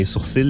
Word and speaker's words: sourcils: Une autre chose que les Français et les Européens prononcent différemment de nous, hein, les sourcils, le nombril. – Ouais. – Alors que nous, on dit sourcils et nombril. Mm. Sans prononcer le L.